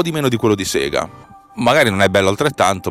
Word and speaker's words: Sega: 9,99$ per un gioco della Sega, che si pronuncia Sega, ricordatevi, perché Sega sta per Service Games, ok? Non sta per Sega, è giapponese.